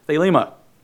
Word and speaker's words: thelema: The Greek word for will here is thelema. Thelema, it's almost spelled like Thelma, T-H-E-L-E-M-A.